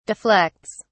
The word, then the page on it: deflects: British English